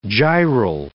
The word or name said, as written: gyral